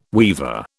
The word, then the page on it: weaver: British English